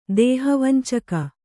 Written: ದೇಹವಂಚಕ

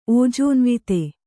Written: ಓಜೋನ್ವಿತ

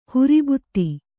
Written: ಹುರಿಬುತ್ತಿ

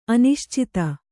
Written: ಅನಿಶ್ಚಿತ

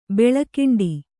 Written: ಬೆಳಿಕೊಂಡೆ

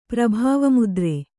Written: ಪ್ರಭಾವಮುದ್ರೆ

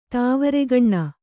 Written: ತಾವರೆಗಣ್ಣು

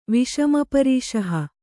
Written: ವಿಷಮಪರೀಷಹ

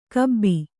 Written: ಖೂಬಿ

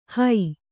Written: ಹೋ

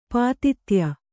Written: ಪಾತಿತ್ಯ

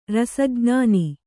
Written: ರಸಜ್ಞಾನಿ

